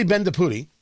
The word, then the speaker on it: Bendapudi: While this is happening, the president of Penn State, her name is Neeli Bendapudi, B-E-N-D-A-P-U-D-I. That's how I pronounce her last name. I believe I've got that right.